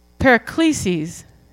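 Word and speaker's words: paraklesis (παράκλησις): It means, it's a Greek word that's pronounced paraklesis.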